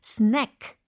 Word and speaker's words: snack: s n a c k 라고 하셔야 돼요. s n a k e 가 아닙니다. 비암이 아니라 s n a c k 라고 발음해 주셔야 합니다.